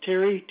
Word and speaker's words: Terry: First name is Terry, T-E-R-R-Y, and the last name is spelled A-L-F-U-T-H.